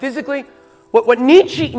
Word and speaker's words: Nietzsche: Fred, Friedrich Nietzsche, or Nietzsche, pronounced Nietzsche in German, famously wrote in his book, uh, Thus Spoke Zarathustra, or something like that.